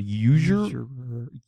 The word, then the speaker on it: usurer: Usurer. It's, it's usurper. – No, not usurper. it's like how do you spell it?